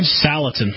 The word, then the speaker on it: Salatin: Salatin. – Salatin.